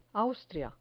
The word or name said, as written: austria